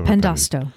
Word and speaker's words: Pandosto: So, although he reverses, is that how you pronounce it? Yeah, oh, Pandosto.